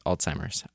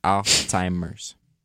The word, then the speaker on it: Alzheimer's: It also says pronunciation note, Alzheimer's. Alzheimer's. In case you forgot.